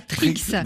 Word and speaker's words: Trkz: Alors, juste dire que Trix, ce sont 4 consonnes, T-R-K-Z. – Et qui se prononce, voilà, Trix.